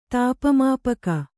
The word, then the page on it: ತಾಪಮಾಪಕ: tāpa māpaka